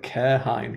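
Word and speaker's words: Cairhien: Is that how they say it in the audiobook, is it? – Yes, Arian. – Carrion. – I always call it Kehrhine because it's like German. It looks German to me.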